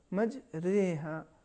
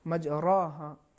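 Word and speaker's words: مجراها: لہٰذا اس اگر کوئی تلفظ اس طریقے سے کرے مج را ہا, یا اگر کہیں مج ری ہا, یہ دونوں تلفظ صحیح نہیں ہے بلکہ درمیانی کیفیت. صحیح تلفظ مج رے ہا.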